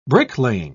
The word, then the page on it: bricklaying: British English